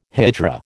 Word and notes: hijra: American English

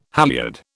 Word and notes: halliard: British English

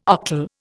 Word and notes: octal: Indian

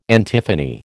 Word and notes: antiphony: American English

